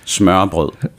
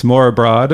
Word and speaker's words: Smørrebrød: Okay, so for those of you at home who are listening, that's s'more abroad, or you might see it as s'more abroad, which actually literally means? – Buttered bread.